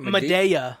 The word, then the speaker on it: Madea: Madea. I'm pretty sure it's Madea.